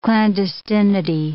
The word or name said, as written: clandestinity